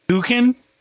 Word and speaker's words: suchen: Suchen is how you pronounce it.